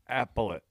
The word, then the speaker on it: appellate: Spell it so I can pronounce it. A-P-P-E-L-L-A-T-E. – Appellant. – But there's no N. – There's appellate? – Appellate? – There's no N in it? – No.